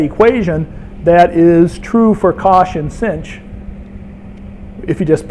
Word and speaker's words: cosh: And it turns out that cosh, that's how you pronounce it, cosh squared minus sinh squared is equal to 1.